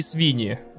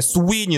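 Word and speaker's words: Sweeney: И HBO Max купил права на экранизацию, ну, Warner Brothers купил права на экранизацию и снял в главной роли Сидни Свини. Э, э, на самом деле Суини, но мне так смешно произносить ее имя с буквой В.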